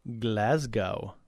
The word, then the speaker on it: Glasgow: And yes, I did overpronounce Glasgow, and that's actually incorrect. As he points out, it is Glasgow.